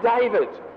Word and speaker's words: David: My name is David. I don't want you to spell it D-A-V-A-D. It's not. It's D-A-V-I-D.